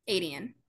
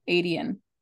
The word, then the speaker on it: Aedion: Aedion. – Aedion.